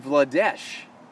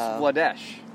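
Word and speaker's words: Vladař: I was talking to Bruins Network on that bonus pod, and he was saying Vladesh. So, I guess in Czech Republic, the R is actually S. – Oh, really? – So, I've been saying it wrong the whole time.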